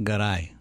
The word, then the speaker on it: Garay: And how is it pronounced in Spain? And In Spanish, it's pronounced Garay.